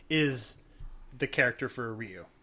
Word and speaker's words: Ryu: Ryu, is it Ryu or Ryu? I've always been confused. – I always called it Ryu. – I called it Ryu when I was a kid. The Japanese pronunciation would be Ryu. – So Ryu nailed it.